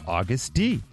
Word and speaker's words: Agust D: Yeah, Suga of K-pop's boy band BTS has announced his debut solo album under a new alias, August D. Now, it's spelled A-G-U-S-T-D. Like space D.